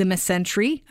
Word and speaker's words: mesentery: No, I think you got it right. – Mesentery. Mesentery. – Yeah. – Like dysentery, but it's a mesentery. – That's right.